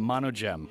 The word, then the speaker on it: Monogem: Now, up next, we've got Monogem with Paraiso.